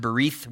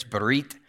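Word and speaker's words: berith (בְּרִית): I believe a few weeks ago I pronounced it berith with a TH, but it's actually a hard T ending there. It's berith. It comes from the root word bara, and it means meat.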